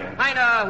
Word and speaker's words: Heine: Heinrich Heine, whose name rhymes with Mina.